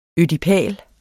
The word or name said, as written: ødipal